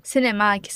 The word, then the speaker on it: CNMAC: CINEMAQ se escreve CNMAC.